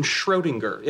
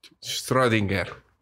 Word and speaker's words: Schrödinger: Y ahí está, así como se pronuncia Schrödinger. Schrödinger.